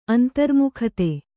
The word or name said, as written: ಅಂತರ್ಮುಖತೆ